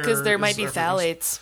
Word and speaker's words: phthalates: So it's phthalates, which has a P in front of it, but the P is silent.